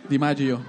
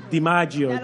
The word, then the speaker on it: DiMaggio: DiMaggio. DiMaggio.